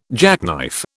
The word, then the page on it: jackknife: British English